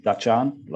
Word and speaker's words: Dutchen: Yeah. Dutchen, like Dutchen. – Dutchen. I always say people like it's like the Netherlands, the Dutch, Dutch Arn. So Dutch Arn is a Matu and Wongai word for sandalwood.